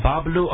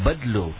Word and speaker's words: Badloo: Is it B-A-B-L-O-W-O, Bablu or Badlu? – B-A-D-L-O-O. – Oh, Badlu. It's pronounced as Badlu.